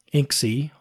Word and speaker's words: inxi: sudo apt install inxi, I'll pronounce it INXI.